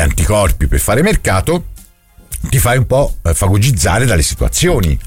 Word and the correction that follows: fagocitare: Non si dice fagocizzare. – Fagocitare? – Quello lì è bello fagocizzare, sì.